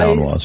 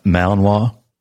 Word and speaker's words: Malinois: Why Malamute, Belgian Malamute? I mean, why? – Malinois, yeah. – How do you pronounce that? – Malinois.